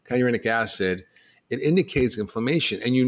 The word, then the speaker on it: kynurenic acid: kinurate, I don't know if I'm pronouncing it right. I was reading, I don't know. Pronounce it. – Kynurenic acid, right.